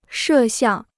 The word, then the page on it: 摄像: shè xiàng